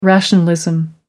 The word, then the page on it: rationalism: American English